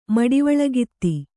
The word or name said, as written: ಮಡಿವಾಳಗಿತ್ತಿ